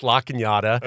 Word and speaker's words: La Cañada: And then after that, you went to La Cañada High School? Am I saying it right? – Yeah, La Cañada. – Because it looks like Canada. – Yeah, L.A., Canada. In fact, there's La Cañada Street, and there used to be a joke on the radio.